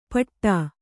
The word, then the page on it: ಪಠ: paṭha